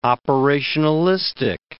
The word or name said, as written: operationalistic